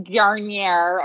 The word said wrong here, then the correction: Grenier: or whatever, however you say his name. – Grenier.